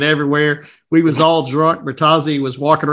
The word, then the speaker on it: Bertozzi: Yeah, it's been Bertozzi for a long time in the racing world, but it's supposed to be pronounced Bertozzi. – Oh, my God. Say it one more time. – Bertozzi.